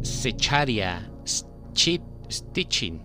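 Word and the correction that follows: Zecharia Sitchin: Así que bueno, este señor es un experto en lenguas muertas, perdón por, este, por esta mala pronunciación, Secharia Sitchin. Ah, mira, bastante fácil.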